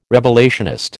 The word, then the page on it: revelationist: American English